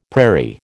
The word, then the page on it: prairie: American English